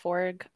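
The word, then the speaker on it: Forge: And what's crazy is like Tobias Forg of Ghost, that's how you pronounce his last name, by the way. The E is silent.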